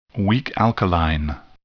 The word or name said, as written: weak alkaline